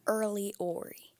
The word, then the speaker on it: RLYORY: So R-L-Y, and that's Early, and then so that's O-R-Y, so it's Ori. So Early Ori. – So it's your initials, but it's spelled R-L-Y-O-R-Y.